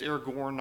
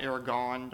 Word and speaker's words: Aragorn: His name's Aragorn, not Aragon. Don't let anybody do you like that. That's how you say his name.